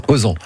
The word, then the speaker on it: Eauzons: Et ça s'écrit, alors, Osons, ça s'écrit E-A-U-Z-O-N-S. – Voilà, E-A-U-Z-O-N-S. Osons.